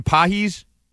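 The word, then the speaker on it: Pagés: Andy, how do you say his name? Pajes. Andy Pajes. Looks like Pages, but it's Pajes.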